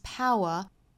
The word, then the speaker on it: power: judge， you can never judge a man's power。 man 后面加的这个 s 应该读浊音的，所以是 z man's power。 power 不要读成抛啊，应该是 ow，上下的开口度要更大，过渡到后面的 ow pow。那后面还有一个音节是 w power，